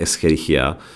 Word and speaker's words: Escherichia: Pokud je mi známo, tak by se to opravdu mělo vyslovovat Escherichia, protože ten pan profesor, eh, který ji popsal, tak se jmenoval Escherich správně, i když většina lidí to čte jako Escherich nebo Escherichia coli.